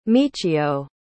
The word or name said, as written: michio